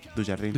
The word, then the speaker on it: Dujardin: Tiene varias películas, Pequeñas mentiras sin importancia, que también trabaja el actor de Artist, el Jean Dujardin. – ¿Cómo se dice? – Dujardin. – Dujardin. – O Dujardin. – Y Dujardin.